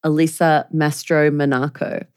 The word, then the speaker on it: Mastromonaco: And it's by the former White House Deputy Chief of Staff, Alyssa Mastromonaco. I did say that right.